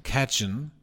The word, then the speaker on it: Cajun: Die auch in Los Angeles angebotene äh, Cajun-Küche, spricht man das so aus? – Bietet, Cajun. – Cajun.